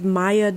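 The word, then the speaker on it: Mia: and her name is Maya Dillon. It's M-I-A, pronounced Maya Dillon.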